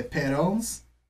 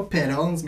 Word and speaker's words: Petals: L'ouverture, la track d'ouverture est Petals. Pas pétales, mais quand on dit pétales, des pétales en anglais, mettons, qui pourrait bien le prononcer ici dans la salle.